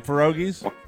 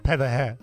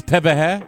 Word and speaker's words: pierogi: Mike, you know, you can actually use the proper Polish pronunciation for pierogi, which is pewahe. – It's pewahe? – Pewahe. – But when you have a lot, it's pierogi.